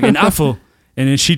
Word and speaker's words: Apfel: And in German it's Apfel.